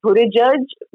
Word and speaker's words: Buttigieg: Pete Buttigieg.